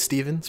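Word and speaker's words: Stephan: My name is Steven, spelled with a PH and an A, so it's like Stefan, but we say Steven.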